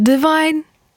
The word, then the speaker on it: Devon: Devon, która za moment, po pierwsze, wymawia się Divine. Żyłam przez ostatnie miesiące w wielkim błędzie.